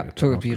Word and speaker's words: Togepi: Togepi. Jetzt habe ich ihn, glaube ich, richtig ausgesprochen. – Ja, Togepi, richtig.